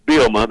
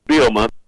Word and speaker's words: Bielema: Is it Bielema? That's the proper way to say it, isn't it? – It's pretty close. It's Bielema. The second he's a little silent, though. Bielema.